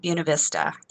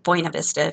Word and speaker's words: Buena Vista: But this is the weekend where we have our family reunion up in Buena Vista, if you're a native, Buena Vista, if you pronounce it correctly.